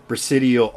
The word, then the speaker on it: Presidio: So, the Lieutenant Colonel Michael Aquino was connected with the Presidio Army. Did I say that right? Presidio. – Bingo.